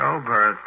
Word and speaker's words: Oberth: The first question, do you mean because Dr. Hermann Oberth, have I pronounced that correctly, incidentally? Is that right? – Yes, Oberth? Correct, yes.